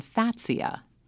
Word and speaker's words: Fatsia: And Zach writes, what's your recommendation for pruning cold-burned new growth on Fatsia? Am I pronouncing that correctly? – Yes.